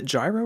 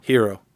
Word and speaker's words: Gyro: Is it Gyro or Euro? – Hero.